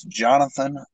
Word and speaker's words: Johnathan: It's Johnathan Lynn. And I'm, of course, the guy that likes to spell it different. I throw an H in there and also throw an extra A instead of an O. So it's J-O-H-N. A-T-H-A-N, and then it's Lynn, L-E-N.